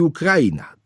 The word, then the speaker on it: Ukraina: To rzeczywiście, no, można akcentować i akcentuje się na drugiej sylabie od końca, ale tradycyjna wymowa umieszcza w słowie Ukraina akcent na kra.